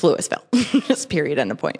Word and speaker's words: Louisville: Not Louisville, Louisville.